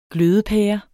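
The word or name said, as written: GLØDEPÆRE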